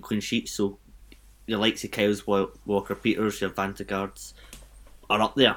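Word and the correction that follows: Jannik Vestergaard: Yannick Vestergaard.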